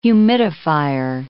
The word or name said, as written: humidifier